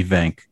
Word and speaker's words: Venk: But everyone just calls me Venk, like vancomycin.